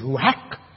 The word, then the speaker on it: ruach: In the original language, I may reiterate it again, the term for spirit in the Hebrew is ruach.